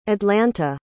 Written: atlanta

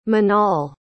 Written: Manal

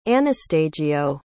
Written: Anastagio

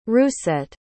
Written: Rousset

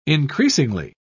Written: increasingly

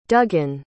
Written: duggan

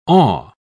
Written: awe

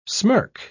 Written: smirk

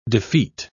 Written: defeat

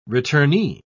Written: returnee

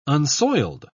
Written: unsoiled

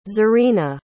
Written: zurina

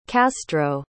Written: castro

